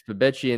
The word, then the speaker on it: Febechi: It's Fabecchi and then Wee Woo. Almost, I mean, people have joked like an ambulance, but that's how you pronounce his name, the North Texas transfer.